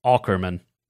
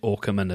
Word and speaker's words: Aukerman: I know you would pronounce it like Aukerman, but it's an AU. So as a Brit, I am duty bound to pronounce it Aukerman as opposed to Aukerman.